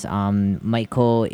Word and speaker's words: Maiko: Maiko is spelled M-A-I-K-O, Maiko. Or if you're going with the variation in Tokyo, it would be Hangyoku. H-A-N-G-Y-O-K-U.